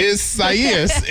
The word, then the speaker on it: Isaias: Is not going to be pronounced correctly. – Isaias is his name.